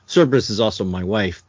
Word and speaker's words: Cerberus: Is it Cerberus or Cerberus, the aardvark? – Yeah, it's misspelled, so the Dave Sim character, you I think it's pronounced Cerberus, I thought.